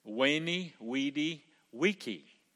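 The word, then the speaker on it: Veni, Vidi, Vici: Or, as I was taught in Chester, you're supposed to pronounce it with a W, Weni, Weedy, Wiki.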